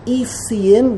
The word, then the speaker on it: Essiim: In Hebrew it's pronounced Essiim, which in English is pronounced Essenes.